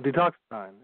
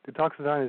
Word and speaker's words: Detoxadine: the product that Deoxidine? – Detoxidine, yes. Detoxidine is D-E-T-O-X-A-D-I-N-E, Detoxidine. – Detoxidine. One more time, D-E. – D-E-T-O-X-A-D-I-N-E, Detoxidine.